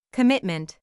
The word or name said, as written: commitment